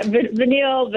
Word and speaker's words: Vanille: How did I do with the name of your shop? – You did excellent. – Oh, come on. Vanille, huh? – Yeah, Vanille.